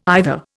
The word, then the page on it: either: Indian